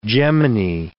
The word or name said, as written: gemini